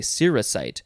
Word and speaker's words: cerussite: I, I want to say cerussite, but it's saying the emphasis is on the r.